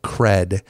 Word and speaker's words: Kred: So recently I came across this cool tool called Cred, as in street cred, as in credibility, but it's spelled K-R-E-D.